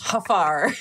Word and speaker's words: Jafar: Jafar Villalobos or something like that. – Yeah. – It's pronounced Hafar.